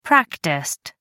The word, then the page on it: practiced: American English